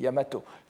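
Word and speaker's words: Yamato (大和): Et Yamato. C'est-à-dire que normalement c'est Daiwa qui est écrit, qui se prononce Yamato, mais on peut aussi le prononcer tout seul.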